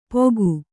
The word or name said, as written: ಪೋಗು